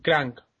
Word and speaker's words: Crank: crack, pero si está bien hecha ah, crank, perdón, lo he dicho mal.